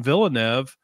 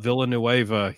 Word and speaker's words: Villeneuve: So it's Villeneuve. It is not Villanueva.